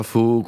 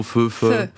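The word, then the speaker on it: phở: Voilà, faux, qu'on prononce faux. Feu. C'est ça, la vraie prononciation.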